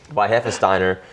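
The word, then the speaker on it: Weihenstephaner: Weinhefesteiner. – There you go.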